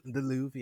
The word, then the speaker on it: Louvre: The Louvre? Is that it? – the Louvre, yeah, in France.